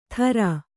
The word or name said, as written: ಥರಾ